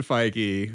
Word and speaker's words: Feige: So Kevin Feige, who I don't know if we know, we have confirmed his last name is pronounced Feige, correct? – That's the only way I've ever heard it pronounced. – Right. – Okay. Because like, it would make me like a Fiji or something like that. – Yeah, or like Fage. I don't know.